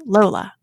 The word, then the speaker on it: Lola: which is Lola.